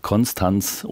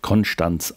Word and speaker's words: Konstanz: Gebhard II., Bischof von Konstanz oder lokal vor Ort Konstanz ausgesprochen, wahlweise auch von Bregenz, geboren am 7.